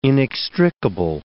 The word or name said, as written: inextricable